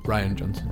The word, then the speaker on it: Rian Johnson: Rian Johnson.